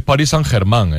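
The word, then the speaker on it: Paris Saint-Germain: Una cosita solo, es París Saint Germain, ¿eh? que lo, habéis pronunciado mal.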